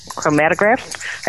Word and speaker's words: chromatograph: Um, we have a, a GC, a gas uh, chroma. I always say this wrong. Chroma Chromatograph. – That's it.